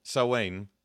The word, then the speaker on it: Samhain: Samhain. S-O-W apostrophe A-N.